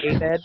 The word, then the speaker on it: Abed: Abed? – He says Abed, yeah. – I'm going to bring Abed with me. – I just kind of, I put the wrong syllable, the wrong emphasis or whatever it is.